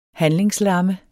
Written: handlingslamme

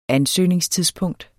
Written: ansøgningstidspunkt

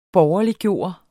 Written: borgerliggøre

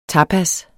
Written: tapas

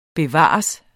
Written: bevares

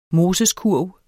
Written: MOSESKURV

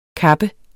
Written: kappes

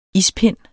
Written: ispind

